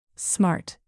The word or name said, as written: smart